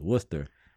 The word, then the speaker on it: Worcester: I should say that is that's how it's pronounced, Worcester.